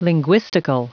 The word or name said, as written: linguistical